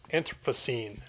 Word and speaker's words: Anthropocene: You call it fragments of the Anthropocene.